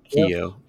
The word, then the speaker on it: Keough: Benjamin Kof? – Keo. Keo. Keo. – It almost looks like it's it should be pronounced yeah. cough.